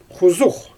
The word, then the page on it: xuzux: Xyzyl